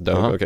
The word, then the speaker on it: Dogue: You say it like Vogue. – Yeah. – Like Vogue, Megan, this Dogue.